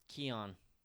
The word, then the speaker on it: Keoghan: There's a G in there somewhere. – Yeah. – K-E-O-G-H-A-N? Yeah, I think he's Irish, so Keon? That's not going to be pronounced.